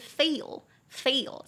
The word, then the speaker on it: fail: 所以真的不好意思，容我自我那个纠正一下，上一集的发音不是很好，是 fail，fail 才是失败哦。